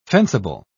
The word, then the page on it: fencible: British English